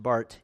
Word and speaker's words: Barth: But a theologian by the name of Karl Barth, it's spelled with a TH at the end, but pronounced Barth, he was a universalist.